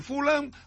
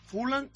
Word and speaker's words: Fulham: Bueno, fichaje del Real Club Celta, cedido a préstamo por el Fulham hasta el próximo 30 de junio. Fulham es, lo dije bien, Fulham, inglés. – Ah, será. – Fulham no, Fulham.